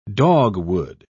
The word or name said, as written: dogwood